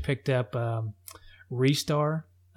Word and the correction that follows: Ristar: I think it's Ristar. It's Ristar.